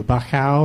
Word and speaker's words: Bauchau: It's spelled B-A-U-C-H-A-U. Bachow or Bachow.